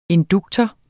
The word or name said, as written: induktor